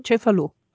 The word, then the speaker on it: Cefalù: We also went to Cefalù. Is that how you say it? Cefalù. Cefalù.